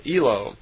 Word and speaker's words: Elo: And Semi in their language is E-L-O, so Elo. And a female Ishwish is called an Ishrish, I-S-H-R-I-S-H. So, in effect, Semyasi is an Elo Ishrish, is how they would pronounce it in her language.